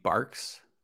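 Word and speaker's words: Barq's: Barg's root beer. – You mean Barks? – Is it Barks? – Apparently it's pronounced Barks.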